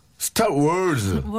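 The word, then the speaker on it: Star Wars: 스타워즈. 스타 월즈. – 월즈. 예, 발음을 제대로 해줘야지.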